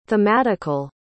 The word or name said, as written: thematical